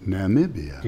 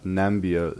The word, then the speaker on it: Namibia: Namibia. – You ready? – Yes. – Scott, it's pronounced Namibia. Namibia. And not Nambia, like your commander-in-chief so eloquently put it.